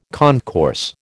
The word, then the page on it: concourse: American English